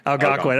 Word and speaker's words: Algonquin: But his plans for drinking alone in the Algonquin? Is that how you pronounce it? – Algonquin.